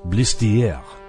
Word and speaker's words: Blistier: Mr. Blistier said that his name was French and was to be pronounced Blistier. But of course, everyone called him the inevitable Mr. Blister.